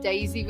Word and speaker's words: Daizy: It's Daizy with a Z, so D-A-I-Z-Y. Gedeon with a G-E-D-E-O-N.